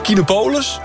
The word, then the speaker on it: Kinepolis: Daar zit een, zeg ik het goed, Kinepolis.